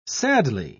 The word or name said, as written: sadly